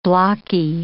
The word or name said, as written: blocky